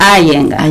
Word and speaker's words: Iyengar: ¿Cómo es que se pronuncia? – Iyengar. – Iyengar, así aprendo. – Se escribe Iyengar. – Y yo digo así, pero pero se pronuncia Iyengar.